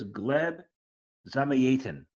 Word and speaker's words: Gleb Zamyatin: His name is Gleb Zamyatin.